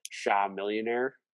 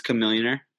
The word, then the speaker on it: Chamillionaire: My artist is, and I'm not 100% sure if I'm pronouncing this right, but Sha Millionaire. – Chamillionaire? I believe it's Chamillionaire.